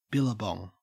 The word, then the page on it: billabong: Australian English